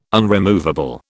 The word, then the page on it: unremovable: British English